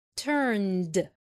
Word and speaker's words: turned: turn turned turn turned. خلي بالكم مش بنقول turned، أنا بكتب الإي لكن مش بنطق الإي، بتحرك من من النه على الد على طول.